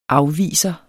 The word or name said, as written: afviser